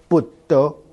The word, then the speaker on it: 佛陀: 所以我后来偶然间才发现，哎，很有趣啊，在我们福建闽南话哈，台湾话也是闽南话系统，我自己是福建闽南人，泉州人，闽南话的佛陀怎么念呢？哎，我们念成布德，所以佛教布嘎 BU，然后这个陀呢是 DO 是 BD，佛陀跟闽南话的不得很接近，比佛陀更接近。